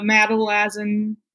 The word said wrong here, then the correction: Mazatlan: narrowmouth toads. – Oh, Mazatlan. Yeah, Mazatlan narrowmouth toads. I knew I was not pronouncing it right.